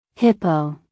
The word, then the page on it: hippo: American English